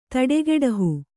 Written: ಥಟ್ಟುಗೆಡಹು

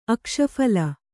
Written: ಅಕ್ಷಫಲ

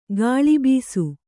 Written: ಗಾಳಿಬೀಸು

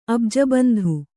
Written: ಅಬ್ಜಬಂಧು

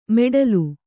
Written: ಮೆಡ್ಲು

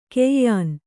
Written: ಗಾಯನಿ

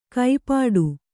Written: ಕೈಪಾಡು

